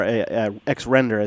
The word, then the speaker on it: XRender: And then the other one is XRender, or XRender is actually how you say it out loud, not when I read it in my head.